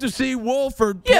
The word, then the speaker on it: Wolford: Yeah, John Wolford, who nobody even knows his actual name, and he dodges the own pronunciation. He's like, yeah, you kind of say the L.